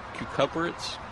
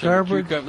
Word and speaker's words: cucurbits: So not just tomatoes, but peppers and some cucurbits. How do you say that word? Squash. – Cucurbits. – Thank you. I always get that word wrong.